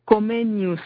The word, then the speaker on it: Comenius: Comenius.